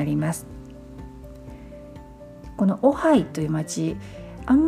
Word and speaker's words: Ojai: オハイ OJAI と書いてオハイというふうに発音します